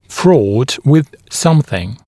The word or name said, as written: fraught with smth